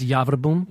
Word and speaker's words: Javerbaum: And does David Javerbaum, I keep saying his name wrong, Javerbaum? – It's Javerbaum.